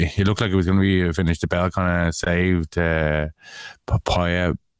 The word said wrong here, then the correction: Paiva: How do you say his name? – Paiva, I think. – Paiva. Mm-hmm.